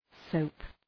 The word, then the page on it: soap: səʋp